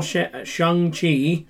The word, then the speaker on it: Shang-Chi: So we saw Shang-Chi. – Shang-Chi. – In the Legend of the Ten Rings. – Yes. – What were you doing? Clapping? – I was clapping because you said it right.